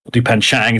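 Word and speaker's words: Dupain-Cheng: Her name is Dupan Cheng. Dupan Chang, as it's pronounced, but it's with an E.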